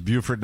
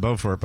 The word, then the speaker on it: Beaufort: I don't know how I found out it was Beaufort, not Beaufort, but I was probably one of those people who mispronounced it.